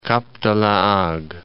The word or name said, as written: Cap de la Hague